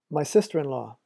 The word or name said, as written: my sister-in-law